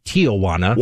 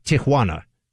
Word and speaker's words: Tijuana: I found out, by the way, it is not Tijuana. – What is it? – Tijuana. – That's how you pronounce it. – I don't speak a word of Spanish, but I know it's Tijuana now.